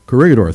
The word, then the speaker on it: Corregidor: You see, they were on the island of, I can't pronounce this name very well, Corridor, I think. What was that? Corregidor, thank you.